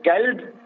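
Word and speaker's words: kalb (كلب): If I'm saying kalb, it means dog.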